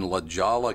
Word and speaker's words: La Jolla: But my first night on the air, I reported that there was a golf tournament in La Jolla, California.